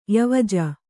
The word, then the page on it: ಯವಜ: kannada